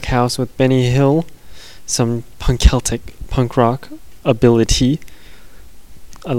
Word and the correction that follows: rockabilly: Or I can never pronounce rockabilly. It's rockabilly, and I keep saying rockability, which is not right.